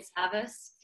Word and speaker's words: Savas: So just uh, to get in touch with me, uh, you can find me on LinkedIn, uh, Tanya Savas,